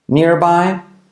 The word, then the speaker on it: nearby: Is there a, is there a subway entrance nearby? Is there a subway entrance nearby? Nearby, near, nearby. La R casi no se dice nearby.